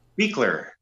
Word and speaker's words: Buechler: How do you say it? – Buechler. – Buechler. – John Carl Buechler. Yeah. – That's right.